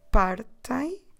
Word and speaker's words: partem: You guys, vocês partem. Try to remember to stress the second to last syllable on this one.